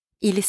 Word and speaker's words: ils: Now, not that both sound the same as il and elle from the singular forms, but the difference is that there is a silent S at the end. For example, they are in the garage. If they are men, I will say, ils sont dans le garage.